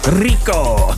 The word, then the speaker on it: Rico: You have to like roll your R when you say that, you know, like a sort of Latin Spanish vibe. Rico.